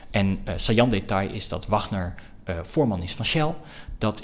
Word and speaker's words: Wagner: Wagner moet ik zeggen. Wagner is de componist. Wagner is de Shell voorman. – Kleine verschillen. – Klein verschil inderdaad. Het wordt vaak als Wagner uitgesproken omdat die naam zo maar heet Wagner. Heette Wagner.